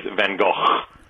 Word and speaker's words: Van Gogh: the Dutch pronunciation is Van Gogh. So she's kind of right, everyone else pronounces it one way.